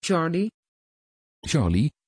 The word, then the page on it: charli: Dutch